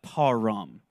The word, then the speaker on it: Purim: And that's a really southern way to pronounce what it's actually in Hebrew, but Purim, spelled P-U-R-I-M.